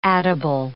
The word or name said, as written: addible